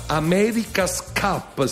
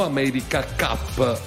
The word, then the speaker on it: America's Cup: America's Cup si dice, no America Cup.